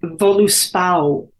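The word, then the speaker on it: Völuspá: Well, it's actually, I mean, the Icelandic pronunciation and the Icelandic language is very close to Old Norse. – Mm-hmm. – And the way they pronounce it, I shot the film in Iceland, so I spent a lot of time there. And the way that they pronounce it, Voluspau.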